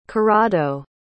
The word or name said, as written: corrado